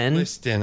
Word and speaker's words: Liston: Liston.